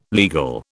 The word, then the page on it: legal: British English